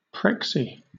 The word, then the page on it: prexy: British English